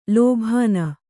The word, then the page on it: ಲೋಭಾನ: kannada